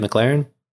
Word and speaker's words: McLaurin: But I was like, you know, looking at this list, and I personally don't really know too much about McLaurin, but at the same time, am I saying that right, McLaurin? – Yeah, you're saying that right.